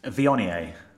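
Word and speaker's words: Viognier: I always call it Vonier, and you've pronounced it correctly, and now it's... How is it again? – Vionier.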